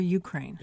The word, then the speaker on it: Ukraine: And is it Ukraine or Ukraine? – It's Ukraine. – Ukraine. – Yes. – Accent on the U.